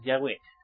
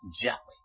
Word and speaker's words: Yahweh: Y hay que pronunciar Yahweh, no como viene escrito YHWH, porque es YHWH. Yahweh.